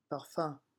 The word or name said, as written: parfum